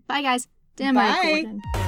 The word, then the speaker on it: Demogorgon: It is pronounced Demogorgon.